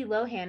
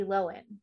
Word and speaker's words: Lohan: It's not Lindsay Lohan. It's Lindsay Lohan.